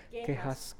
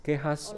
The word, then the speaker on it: Quejas: Is it Quejas? – Quejas. – Quejas.